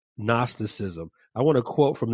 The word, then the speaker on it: Gnosticism: Gnosticism is spelled G-N-O-S-T-I-C-I-S-M, Gnosticism.